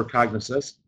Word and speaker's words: Cognisess: I'd like to find out exactly what you guys do for Cognisess, which, by the way, am I pronouncing that correctly? – You're pronouncing that perfectly.